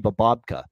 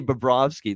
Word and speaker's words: Bobrovsky: We've been robbed of Charles not being unable to hear Charles Barkley say Sergei Bobrovka. pronouncing Sergei Bobrovsky.